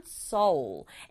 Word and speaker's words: Seoul: 서울을 영어로 써서 발음을 하면 Seoul. L 소리를 살려서 이렇게 Seoul 하고 발음하게 돼요.